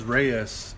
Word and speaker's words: Reyes: My last name is Reyes, and it's spelled R-E-Y-E-S.